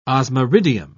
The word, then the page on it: osmiridium: British English